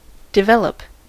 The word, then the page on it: develop: dɪˈvɛləp